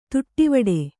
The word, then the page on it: ತುಟ್ಟಿವಡೆ: tuṭṭivaḍe